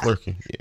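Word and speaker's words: Flerken: I'm probably saying that wrong. Flerkin? – Yeah. Flerkin, yeah.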